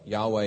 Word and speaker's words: Yahweh: Yahweh is the basic word for the God of Israel, if you're unfamiliar. You may have heard Jehovah before, but uh, kind of the pronunciation nowadays is Yahweh, the God of Israel.